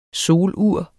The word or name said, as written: solur